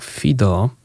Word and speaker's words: Hideaux: Następny zespół nazywa się Fido. Pisze się to Hideaux, tak, taka pisownia z francuskiego, aczkolwiek ten multiinstrumentalista mm, nie pochodzi z Francji, pochodzi on z USA, nazywa się Xavier Fido.